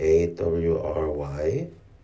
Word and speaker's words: awry: 아 이, 이거 발음이 어려워서 저도 항상 틀리는데 어, 스펠링 A W R Y 야, 이거 어떻게 어떻게 발음하는 건지 모르겠습니다 이게 음, 확인을 하고 갑시다. 네, 그래도 명색이 FT 읽어주는 남자인데 읽을 줄 모르면 창피합니다. awry, awry.